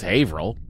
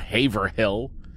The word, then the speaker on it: Haverhill: Haverhill. That's how you pronounce Haverhill. It's not Haverhill.